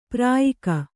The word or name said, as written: ಪ್ರಯೋಗ